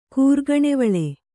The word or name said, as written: ಕೂರ್ಗಣೆವಳೆ